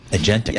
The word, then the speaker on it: agentic: You're very agnetic. – Yeah, exactly. I'm getting there. Agentic. Yeah. All right. – And also, remember this. – Is that how you say it? Agentic? – Agentic AI. – Agentic. That's what our folks here say.